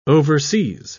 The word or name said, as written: overseas